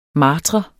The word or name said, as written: martre